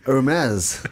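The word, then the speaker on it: Hermès: Yeah, and very quickly, because Wong is in the house, Hermes.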